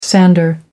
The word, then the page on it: sander: American English